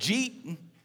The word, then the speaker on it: Jeet: I mean, when I was growing up in Texas, we used to say it like this. They would say like, "Jeet yet?" It was like all one word, "Jeet," like J-E-E-T, like "Jeet yet?